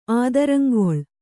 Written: ಆದರಂಗೊಳ್

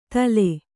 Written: ಥಾಲಿ

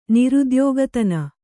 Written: ನಿರುದ್ಯೋಗತನ

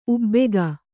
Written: ಉಬ್ಬಿಕೆ

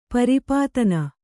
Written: ಪರಿಪಾತನ

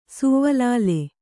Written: ಸುವ್ವಲಾಲೆ

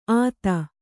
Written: ಆತ

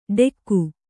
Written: ಡೆಕ್ಕು

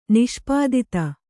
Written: ನಿಷ್ಪತಿತ

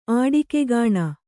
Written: ಆಡಿಕೆಗಾಣ

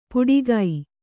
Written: ಪುಡಿಗೈ

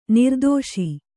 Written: ನಿರ್ದೆಸೆ

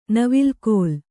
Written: ನವಿಲ್ಕೋಲ್

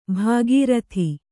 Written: ಭಾಗೀರಥಿ

